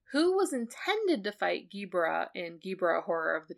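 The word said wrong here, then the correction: Ebirah: There's no G. It's Ebira.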